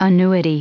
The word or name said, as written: annuity